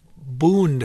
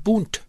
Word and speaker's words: Bundt: and it was called a Bund. They referred to it as a Bund pan, B-U-N-D. But they pronounced it in German, D's sound like T's, and so they pronounced it Bundt.